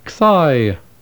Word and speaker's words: xi: The letter xi, pronounced x, as the letter x is pronounced in the English word tax, x.